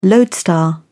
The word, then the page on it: lodestar: British English